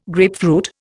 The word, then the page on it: grapefruit: Indian